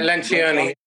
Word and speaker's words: Lencioni: Is that how you pronounce his name? – Lencioni. – Yes, this 5 Dysfunctions of a Team.